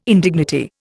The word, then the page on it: indignity: Indian